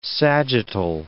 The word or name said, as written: sagittal